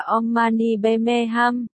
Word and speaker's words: Om Mani Padme Hum: An Mane Bami Hong đọc là Om Mani Padme Hum.